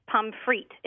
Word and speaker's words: pommes frites: You actually don't pronounce the S on the end of pommes frites. It's just pommes frites. – Well, it's more than one French fry, isn't it? – We hope we get a whole bunch of them. – Yep, even the plural doesn't have the S pronounced.